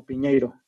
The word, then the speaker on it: Piñeiro: Es este Pineiro o Piñeiro. se escribe Piñeiro sin H.